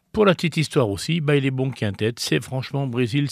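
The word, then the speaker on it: Baile Bom: Je me demandais comment ça se prononçait. Eh bien, parce que ça s'écrit B-A-I-L-E puis B-O-M. Donc voilà, je disais Baillet Bon, machin. Non, non, c'est Baillet Bon parce que quand le bal a été bon, on dit que le bal est bon.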